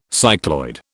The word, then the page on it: cycloid: British English